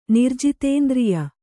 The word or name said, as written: ನಿರ್ಜಿತೇಂದ್ರಿಯ